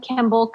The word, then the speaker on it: Kimball: Kim Kimball Coaching. My last name is spelled K-I-M-B-A-L-L.